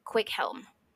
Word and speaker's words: Cwichelm: There is an Old English name that was very, very common, and it is pronounced Quickhelm. But it is, I'll tell you how it's spelled. C-W-I-C-H-E-L-M.